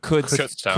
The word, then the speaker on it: Kutztown: Somebody told me it's pronounced like the word could. – Like could. – It is pronounced like that. – Kutztown.